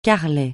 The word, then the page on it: carrelet: francais